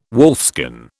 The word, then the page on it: wolfskin: British English